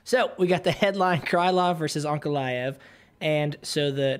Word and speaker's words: Krylov: I should have read this headline a little more in advance because I know Ankalaev is the last name, but Krylov, am I saying that right, PT? Yep. Rob? Okay, cool. Yeah.